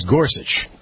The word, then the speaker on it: Gorsuch: It's Gorsuch, right? – Gorsuch, yeah.